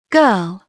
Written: girl

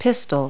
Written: pistil

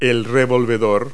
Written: turner

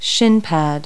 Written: shin pads

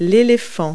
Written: elephant

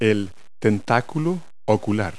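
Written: eyestalk

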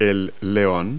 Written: male lion

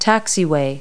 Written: taxiway

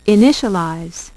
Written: initialize

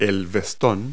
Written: jacket

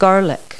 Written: garlic

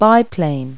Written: biplane

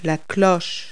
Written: cloche

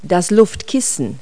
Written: airbag

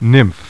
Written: NYMPH

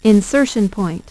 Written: insertion point

